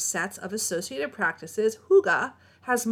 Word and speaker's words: hygge: Okay, hygge, which is spelled very differently. – Hygge. – H-Y-G-G-E, but it's said hygge.